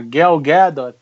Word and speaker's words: Gadot: E então as pessoas estão, porque a galgador, galgador, e, e, ou então você vê as pessoas que puxam mais, acham que tem que pronunciar em inglês, né? A Gal Gadot.